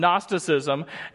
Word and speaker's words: Gnosticism: Just pretend the G isn't there and you'll pronounce it correctly. Um, Gnosticism.